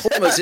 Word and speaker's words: Hormuz: I love the idea we're going to have more economic havoc coming courtesy of the Straits of Hormuz. Oh, marvellous, isn't it? – You didn't even know where they were. And that's Hormuz. It's not hummus.